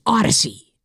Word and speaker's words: Audacy: Hey, the Odyssey app is spelled A-U-D-A-C-Y. It's a made-up word, so it's not what you think. A-U-D-A-C-Y.